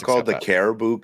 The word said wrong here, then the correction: Carabao: Cup. It's called the Carabao Cup.